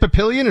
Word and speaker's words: Papillion: It looks to me like the French for butterfly, papillon, which Clay's probably wondering, Buck, why are you doing all the pronunciations today? – There you go again. – Is it Papillion? Is that right? – Yes, that's correct.